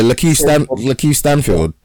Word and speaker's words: Lakeith Stanfield: Yeah, Lakeith Stanfield.